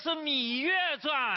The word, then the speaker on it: 芈月传: 哎呦，那是《芈月传》。 – 芈月传。 – 那个念米呀，啊，我念了好几个月的半了。哎呦，他还念成半了，这分明就是个半情啊！